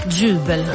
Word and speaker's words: Jubel: J-U-B-E-L. – Hvor er de fra? – Det er en svensk duo. – Jubel. – Jubel. Okay, så titlen er K-L-I-N-G-A-N-D-A.